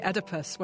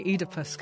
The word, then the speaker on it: Oedipus: I have to really remember that you say Oedipus, where I would say Oedipus, because this is one of those transatlantic things.